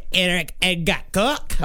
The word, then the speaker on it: Eric Edgar Cook: Can we please pronounce it correctly? Eric Edgar Cook. – All right. I'm not sure that's properly. It's approximately.